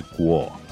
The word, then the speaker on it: Kuo: His last name is spelled Kuo, but in reality, its original pronunciation is Guo.